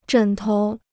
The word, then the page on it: 枕头: zhěn tou